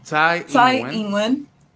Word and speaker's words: Tsai Ing-wen: Sin tono. Eh, en, Tsai Ing-wen. Sí, es que la cosa es que tiene tonos, pero está bien.